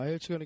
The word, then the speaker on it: IELTS: 아, ILC가 그러니까 스펠링으로 하면 I E L T S. – 네. 이걸 저희가 ILC라고 이제 부르죠.